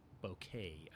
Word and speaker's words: bokeh: bokeh although i'm aware that the official pronunciation is bouquet i believe but i'm going to say bokeh because that's what everyone says and you know what I'm talking about.